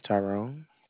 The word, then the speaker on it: Tyrone: My name is Tyrone.